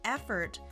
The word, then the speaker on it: effort: effort to pass the exam それではみんなで発音してみましょう Repeat Make an effort Make an effort Sounds great! 発音のコツは O の音は忘れて大丈夫。 FRT ここをつなぐ意識で Let's try again Effort Effort One more time Make an effort Sounds great!